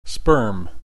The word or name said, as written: sperm